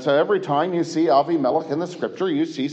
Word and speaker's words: Avimelech: And Avimelech, yes, I know, we ordinarily pronounce that Abimelech.